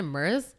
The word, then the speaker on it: MERS: Is that how you pronounce it? MERS? – I think it is.